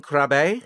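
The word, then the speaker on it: Krabbé: We have, and I don't know if I'm going to say his name right, but Yaron Krab. – Yaron Krabbe. – Yaron Krabbe, who's a Dutch actor who I also I really put him.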